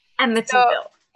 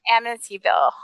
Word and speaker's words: Amityville: And it is all about the history of the Amityville. I have so much trouble saying this word. Amityville Horror House. – Amityville. – Amityville Horror House.